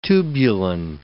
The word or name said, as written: tubulin